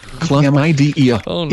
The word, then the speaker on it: chlamydia: Chlamydia, pronounced chlamydia, is a sexually transmitted disease, STD, caused by bacteria called Chlamydia trachomatis.